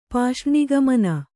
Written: ಪಾಷ್ರ್ಣಿಗಮನ

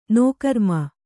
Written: ನೋಕರ್ಮ

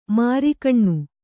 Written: ಮೆರುಗೆಣ್ಣೆ

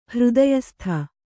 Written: ಹೃದಯಸ್ಥ

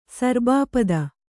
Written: ಸರ್ಬಾಪದ

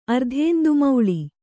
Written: ಅರ್ಧೇಂದುಮೌಳಿ

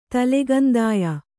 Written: ತಲೆಗಂದಾಯ

